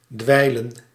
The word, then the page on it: dweilen: /ˈdwɛː.lə(n)/; /ˈdwɛi̯.lə(n)/